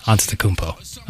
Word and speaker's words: Antetokounmpo: And you got props yesterday for saying Giannis Antetokounmpo during your updates. – Yes, Antetokounmpo. – I got praise for pronouncing it correctly, and you just pronounced it incorrectly.